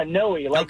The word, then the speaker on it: Noe: Yeah, Noe, Joey, but with an N.